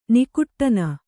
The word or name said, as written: ನಾಗಠಾಣ